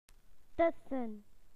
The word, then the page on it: between: British English